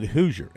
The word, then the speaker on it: Hauser: They pronounce it Hoosier, H-A-U-S-E-R. Nolan Hoosier, like Hoosiers of Indiana.